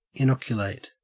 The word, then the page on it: inoculate: Australian English